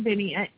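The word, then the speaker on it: Byyny: It's B-Y-Y-N-Y. It's Jan Binney.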